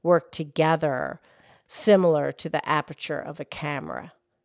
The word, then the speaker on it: aperture: Aperture. – Right. It's only three syllables. It's not aperture. It's just aperture.